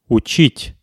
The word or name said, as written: uchit